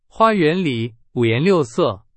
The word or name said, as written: 五颜六色